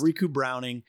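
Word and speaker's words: Ricou Browning: His name's Riku Browning. – I actually think that's right? – Pretty correct. – Yeah, okay. – I'll be honest. – Riku Browning.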